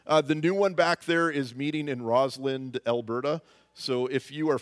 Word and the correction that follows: Rosalind: from that part of the world, if you're one of those rare people from down there, Did I say that wrong? Is that why you're all laughing? You know, I even looked it up on the internet before I came up here. I really did. And I wrote it down. It has a D at the end, doesn't it? Rosalind.